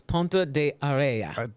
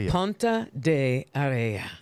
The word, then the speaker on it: Ponte de Areia: So the pronunciation, the title of this song is Ponte de Areia. Ponte de Areia.